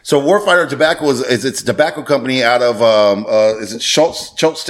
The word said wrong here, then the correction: Schertz: Texas, I think it's. – Shirts, Texas. – Shirts, Texas, which is south of San Antonio. – It's not spelled like shirts, though. – No, it's spelled S-H-U-R-T-S or something. No, S-C-H-E-R-T-Z.